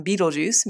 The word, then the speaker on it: Betelgeuse: Vega, for example, means swooping eagle, and Betelgeuse, pronounced by some Betelgeuse, means the right hand of the central one.